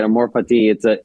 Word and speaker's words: Amor Fati: Oh yeah, that's how you say it. Amor Fati. It's actually Latin, you know.